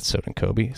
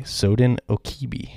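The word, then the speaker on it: Sodinokibi: No, it's not Soden Kobe. Soden Okibi.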